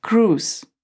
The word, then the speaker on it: cruise: Обратите внимание, что слово пишется по буквам, продиктую C-R-U-I-S-E, а произносится как «круз».